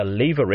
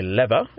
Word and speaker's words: lever: Now, when you think about what a lever is, or a lever, as they pronounce it in North America,